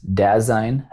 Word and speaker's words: Dasein: It is Dasein Health. So that is the correct pronunciation. – And don't let anybody tell you otherwise.